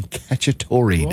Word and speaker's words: Cacciatore: National Chicken Cacciatore Day.